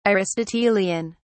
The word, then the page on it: aristotelian: British English